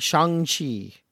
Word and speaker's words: Shang-Chi: Shang-Chi.